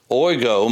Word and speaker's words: oigo: It's the word dia, a preposition dia, and then another word, which is a preposition, ana, A-N-A. So you have D-I-A, A-N-A, and then oigo. Oigo means to open, O-I-G-O, long O, as in omega.